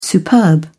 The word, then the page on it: superb: British English